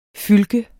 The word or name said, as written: fylke